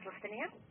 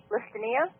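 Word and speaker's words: Lastenia: My name is Listenia. – Your name is what? – Listenia.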